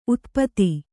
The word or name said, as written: ಉತುಪಾತ